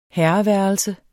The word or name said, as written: herreværelse